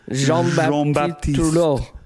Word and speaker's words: Jean-Baptiste Trudeau: Say it like the French say it. – Jean-Baptiste Trudeau.